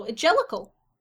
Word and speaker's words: Jellicle: No, Jellicle.